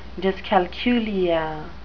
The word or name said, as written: dyscalculia